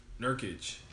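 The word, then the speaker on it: Nurkić: Nurkic.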